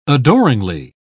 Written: adoringly

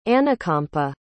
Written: anukampa

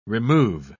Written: remove

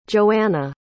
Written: joanna

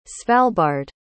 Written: svalbard

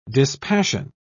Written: dispassion

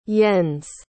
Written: jens

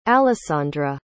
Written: allessandra

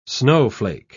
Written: snowflake

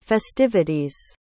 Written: festivities

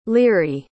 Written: leary

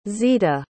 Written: zita

